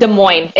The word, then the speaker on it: Des Moines: Is the S silent? Des Moines or Des Moines? – Des Moines. It is silent. Des Moines. It's French.